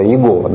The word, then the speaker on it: Егова: Про Егова, про не Егова, а Егова, да? Егова, правильно.